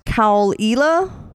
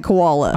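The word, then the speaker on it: Caol Ila: So I think it's pronounced Kaul-ila. But Ben calls it koala.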